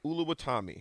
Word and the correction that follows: Oluwatimi: Am I saying it right, Nick? – Olu Oluwatomi. Oluwatimi.